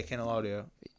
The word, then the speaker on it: Candelario: Oh, Candelario? – Yeah, Candelario.